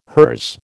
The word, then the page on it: hers: American English